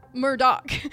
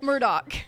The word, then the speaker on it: Murdaugh: It's Alec Murdaugh. Murdoch. It's Alec Murdoch. – Well, it's A-L-E-X.